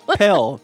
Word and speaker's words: Pell: This is Budget King, and could you guys inform me to what a pale grant is? – A pale grant? – Am I saying that correctly? – No. Pell. P-E-L-L.